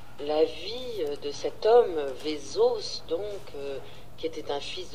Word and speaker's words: Vesaas: Alors son nom est difficile à prononcer, il s'appelle Tarjei de son prénom, Vesaas en français, mais avec deux A, mais les Norvégiens disent Vesaas, parce qu'il y a un petit O au-dessus du A.